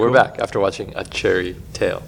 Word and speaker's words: Chairy: We're going to talk about a cherry tail, and that's chair like the thing that you sit on. So if you're searching for it, it's spelled C-H-A-I-R-Y, and it's directed by Claude Joutre and Norman McLaren from 1957.